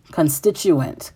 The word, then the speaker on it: constituent: Masturbation is one of the constitute parts. I cannot pronounce that word. Constituent parts. There we go.